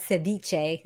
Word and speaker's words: ceviche: Now tell me, if I want to pronounce it correctly, how do we pronounce the word that's in the name of the dish that you're making? – So just ceviche.